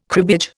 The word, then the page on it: cribbage: Indian